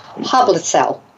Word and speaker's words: Hoblitzelle: And my guest is Olivia Hoblitzelle.